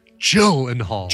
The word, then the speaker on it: Gyllenhaal: It's pronounced Gyllenhaal.